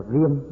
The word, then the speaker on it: diệm: Và đến danh hiệu thứ năm là diệm vương quang, hay là còn đọc là viêm, nhưng mà thường thường thì đọc viêm cũng được, đọc diệm cũng được, diệm hay diễm cũng được.